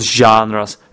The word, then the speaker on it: genres: All these are genres, not genres, genres, okay? So there's O sound, not long A, as I used to say it, all right?